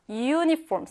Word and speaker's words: uniform: 자, uniform. 쌤이 방금 어떻게 발음했어요? U라고 발음했죠. 쌤, 그러면은 아, 에이오의 요 발음 아닌가요? 할수 있는데 얘는 우 발음입니다. 영어에서 U 발음은요, U, Y 발음이다라고 해서 자음 발음으로 취급을 합니다.